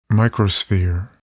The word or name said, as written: microsphere